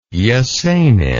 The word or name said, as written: Esenin